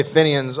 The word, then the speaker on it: Fenian: because they say it like onion, Fenian. But we say it differently in America because we talk differently.